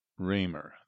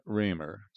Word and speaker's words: Roemer: Raymer, that's spelled R-O-E-M-E-R, Paxton. Gloria M. Raymer, 73, of Paxton, died Sunday, December 7th at Carl Foundation Hospital in Urbana.